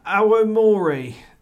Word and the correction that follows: Aomori: Have I pronounced that right? Would you like to have a better feel like there's something you've got to do with the O. – Shingu. – Maybe. – Shingo Aomori. Aomori.